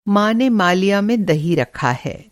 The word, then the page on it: मालिया: مالیہ